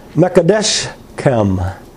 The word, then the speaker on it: Mekadeshkem: This title in the Hebrew is Jehovah Mekadeshkem, Mekadeshkem. Mekadeshkem. That's just to show you I could pronounce it.